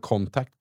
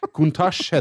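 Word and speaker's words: Countach: Var det Contact? Contash? Contact, contact, Contash heter den.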